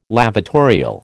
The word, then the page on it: lavatorial: American English